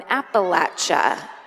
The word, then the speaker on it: Appalachia: Remember the old trick? That's now you know the correct way to pronounce the famous mountain chain. If you're a prosecuting attorney, Squeaky Fromm will throw an Appalachia. – Oh! – That's how you pronounce an Appalachia.